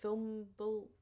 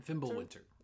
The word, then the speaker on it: Fimbulwinter: There was also what I'm going to get into later, how do you say it? – Fimbulwinter? – Fimbulwinter.